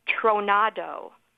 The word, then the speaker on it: tronado: Our word tornado actually comes from the Spanish word tronado, which means storm, and it's spelled T-R-O-N-A-D-O, tronado.